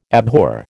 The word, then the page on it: abhor: American English